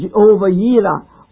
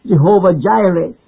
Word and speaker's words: Jehovah Jireh: Verse 1, which says, I shall not want, tells us of Jehovah Yireh, or as commonly pronounced, Jehovah Jireh.